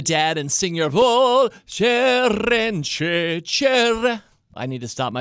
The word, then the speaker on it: Cerence: The name of this company is Cerence. It's spelled C-E-R-E-N-C-E, Cerence.